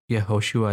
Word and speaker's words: Yehoshua: And wasn't his name actually Joshua, which is pronounced Yehoshua in Hebrew?